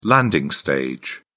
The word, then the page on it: landing stage: British English